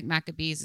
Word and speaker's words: Maccabees: And I didn't know if I said that right, but I think it's Maccabees. Do you? – Yeah, it looks like Maccabees is pretty right to me.